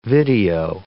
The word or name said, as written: video